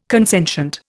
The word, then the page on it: consentient: Indian